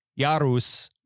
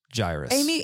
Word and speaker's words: Jairus: We had a big discussion about how to pronounce Jairus. – Amy said Jairus, and we would have said Jairus, but when I looked it up, like the actual Greek, like phonetics, like how to pronounce it was like Jairus.